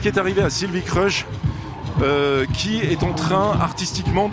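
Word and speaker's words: Kreusch: Ça se prononce comme ça s'écrit. Pour nous, en tout cas, c'est comme ça qu'on le dit. Ça peut être différent dans chaque pays, mais même en Belgique, ils ont tendance à compliquer les choses. Mais de nombreuses personnes prononcent Crush, et je préfère vraiment ça.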